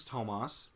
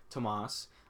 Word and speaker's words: Tomas: Saying, hey, what's up? My name's Tomas. Like, toes on your feet and moss on the trees. Tomas.